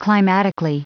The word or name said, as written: climatically